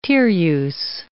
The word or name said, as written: Tereus